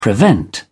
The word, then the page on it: prevent: British English